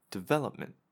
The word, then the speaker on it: development: And the reason for that is that you are actually indicating, pay attention to this second syllable, development. The second syllable is the most stressed one out of all of the different syllables.